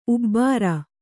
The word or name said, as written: ಉಬ್ಬಾರ